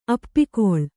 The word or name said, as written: ಅಪ್ಪಿಕೊಳ್